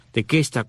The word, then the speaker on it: Tequesta: pero es tequesta, tequesta con Q, T-E-Q-U-E-S-T-A, los tequesta, y los otros son los jaega.